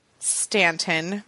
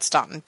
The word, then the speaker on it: Staunton: So anyway, speaking of podcast listeners, needed to tell you, I met a listener in Stanton, Virginia. I really wanted to pronounce that Staunton, but I didn't. You're welcome, Stanton.